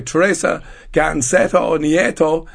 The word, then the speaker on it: Teresa Ganceto Nieto: And I'm delighted to be joined by Teresa Ganceto Nieto. She's a director of the Spanish Tourist Office. And I hope my pronunciation on that was okay. – That was perfect.